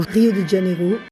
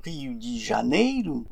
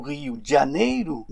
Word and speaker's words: Rio de Janeiro: Rio de Janeiro depuis des lustres. Pour le prononcer correctement, il faudrait dire Rio de Janeiro ou Rio de Janeiro, mais non pas Rio de Janeiro.